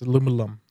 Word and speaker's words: aluminum: So it's aluminum. – Aluminum? Yeah. Or if you're British, it'd be aluminium.